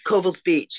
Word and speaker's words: Covell's: is coming ashore at is it pronounced Covell's Beach? – Covell's Beach, yes.